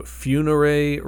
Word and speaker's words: Funere: And then just got signed to Funere Records, at least that's how I'm pronouncing it. It's F-U-N-E-R-E with a little accent over the E.